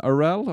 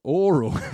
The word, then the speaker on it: Orell: On today's episode, we've got on Ryan Orell. Am I saying that right? – Orell, good, yes. – And yeah, nice to meet you, Ryan. – It's good to meet you. Most people say Oral. – Oral.